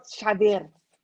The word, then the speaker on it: Xavier: Señor Xavier. Got to say with the gotta roll your R's.